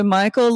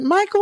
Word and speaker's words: Michal: Is it Michal or Michal? – I've heard it pronounced both. I've heard both. I usually say Michal.